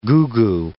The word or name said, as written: goo-goo